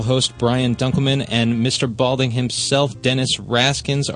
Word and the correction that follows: Haskins: are the guests. – Oh. – I hope I'm saying that right. Haskins, Dennis Haskins.